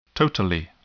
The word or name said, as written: totally